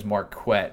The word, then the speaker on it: Marquette: Um, oh, there was the punt that uh, Marquette, and yes, it's his name is Marquette, as he pronounced before the game.